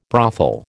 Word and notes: brothel: American English

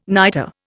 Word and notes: niter: Indian

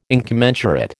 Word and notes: incommensurate: American English

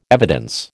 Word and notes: evidence: American English

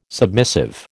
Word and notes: submissive: American English

